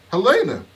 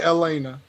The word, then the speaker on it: Helena: Helena, what? – No, you said Elena. You ain't pronounced the H at all.